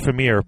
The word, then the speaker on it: Fimir: It looks like they're making Fimir or Femir, F-I-M-I-R. How do you pronounce it? – I thought it was Fimir, but Fimir, I think that must be right.